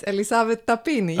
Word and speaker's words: Elisavet Tapini: Yes, Elisavet Tapini.